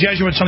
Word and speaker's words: Jesuits: No, it's spelled J-E-S-U-I-T. It's some type of, like, from the Vatican. – Yo, Jesuits. – Jesuits. Yeah, do you know anything about that? That's just a question I have. Yeah, sure. I'll tell you about the Jesuits on the other side.